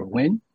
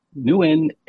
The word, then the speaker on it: Nguyen: But the actual pronunciation is Nguyen, which has this inflection eh, thing at the end.